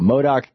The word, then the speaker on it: Modoc: How do you say that? – It's Modoc. – Okay, the Modoc County Board of Supervisors in the state of California.